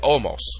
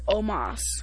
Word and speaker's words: Omos: Omos. Well, that's not how you pronounce it. – Say it again. Say it, because I say it Omos. – Brock Lesnar versus Omos. – Alright, I'll take your word for it.